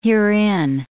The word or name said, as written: herein